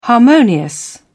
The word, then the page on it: harmonious: British English